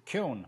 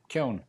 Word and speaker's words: Keown: Keown. Not bad. That's close. Keown. Imagine that it rhymes Keown. – With moan and groan and bone and stone. And stick a K on the front. Keown.